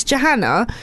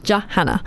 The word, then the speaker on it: Johanna: So it's Johanna, like J-A Hannah, Johanna.